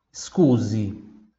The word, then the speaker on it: scusi: Excuse se escreve S-C-U-S-I. Excuse. S-C-U, SCU, S-I. Scuse. Mais uma vez, SCUSE, excuse. Muito bem, também mesmo som igual o O e o U, né?